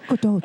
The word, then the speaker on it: Gadot: You have to do the accent and kind of blend them. Gadot.